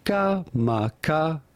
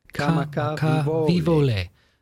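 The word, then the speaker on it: Kamakavivole (Kamakawiwoʻole): We are looking at how to pronounce the name of native Hawaiian singer, lyricist, and musician, Israel Kamakavivole. – Israel Kamakavivole. – Israel Kamakavivole.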